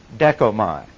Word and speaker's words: dechomai: However, there is a second word that also means to take, and it is D-E-C-H-O-M-A-I, pronounced dekomai.